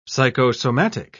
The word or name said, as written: psychosomatic